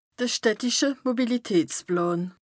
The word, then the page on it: de städtische Mobilitätsplàn: Bas Rhin